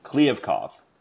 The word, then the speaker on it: Kliavkoff: Pronounce his name for me. – Kliavkoff. – Kliavkoff.